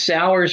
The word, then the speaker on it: Sowers: It's Sours for Pastors, but it's spelled Sowers. So Sowers.